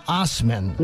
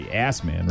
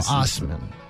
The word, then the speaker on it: Assman: By the way, he pronounces his name Osman. I was yeah, going to ask you that. Uh, it can't be. You can't be Assman, right? No, Osman.